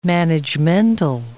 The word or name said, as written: managemental